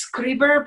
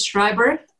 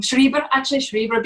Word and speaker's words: Schreiber: Well, in Italy, it's Schreiber. In Germany and Switzerland, it's Schreiber. Schreiber, because from the word writing, Schreiben.